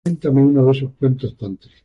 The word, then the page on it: cuentos: ispanu